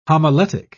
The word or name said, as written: homiletic